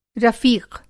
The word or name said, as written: रफ़ीक़